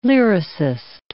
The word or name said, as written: lyricist